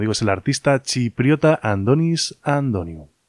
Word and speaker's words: Antonis Antoniou: Y ahora Andonis Antoniou, escrito Antonis Antoniou, aunque parece ser que se pronuncia más bien así, Andonis Antoniou, nos avanza lo que va a ser su presentación en solitario.